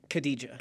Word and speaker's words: Khadija: Khadija. – Khadija.